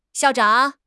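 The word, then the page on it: xiao4 zhang3: tone 4; tone 3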